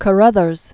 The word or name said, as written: Wallace Hume Carothers